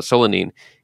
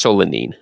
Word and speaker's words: solanine: I don't know about the pronunciation. I think it's solanine.